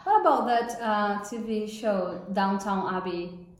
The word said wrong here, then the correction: Downton: That's really Downton. – Downton.